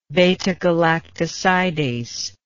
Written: beta-galactosidase

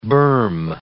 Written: berm

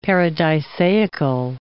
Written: paradisaical

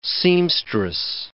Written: seamstress

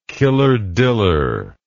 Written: killer diller

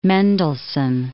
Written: Mendelssohn

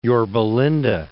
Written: Yorba Linda

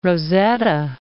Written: Rosetta

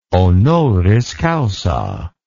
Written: honoris causa

